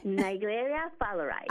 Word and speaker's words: Naegleria fowleri: Cuéntame del Naegleria fowleri. ¿Cómo se dice? – Naegleria fowleri. – Ah, fowleri. Okay, fowleri.